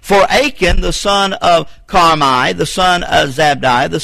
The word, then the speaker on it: Carmi: His son was Carmi. It's pronounced K-A-R-M-I.